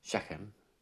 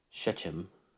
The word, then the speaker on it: Shechem: So uh, this daughter of his um, they they had moved to just on the outskirts of this city called Shechem, or Shechem, if you want to pronounce it in English. That ch is a is a ch, which is fun.